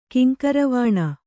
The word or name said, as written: ಕಿಂಕುರ್ವಾಣ